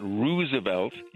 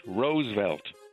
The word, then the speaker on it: Roosevelt: It's not Roosevelt. It's two syllables, Roosevelt.